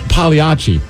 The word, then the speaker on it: Pagliacci: Well, Pagliacci. – Pagliacci. – Yeah. – You don't put the G in there? – No, you're not supposed to.